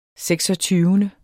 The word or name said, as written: seksogtyvende